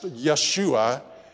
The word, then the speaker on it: Yeshua: The Hebrew name for Joshua is pronounced Yeshua, and it, like Jesus' name, means deliverer or Savior.